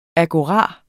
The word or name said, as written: AGORAEN